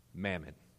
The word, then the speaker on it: mammon: You know how you pronounce it in the Greek? Mammon.